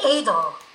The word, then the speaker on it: Adel: I think it would be Adel. – Oh, I didn't know how to pronounce it. Adel, like as in Edelweiss, or... – Yeah, exactly.